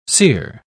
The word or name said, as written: cere